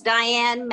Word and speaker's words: Diane: Okay, so it's Diane Mattingly. That's D-I-A-N-E.